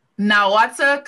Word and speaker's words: Nahuatl: And I don't want to say this incorrectly, so please correct my pronunciation of this. The Nahuatl cosmology? – Nahuatl cosmology.